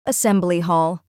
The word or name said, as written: assembly hall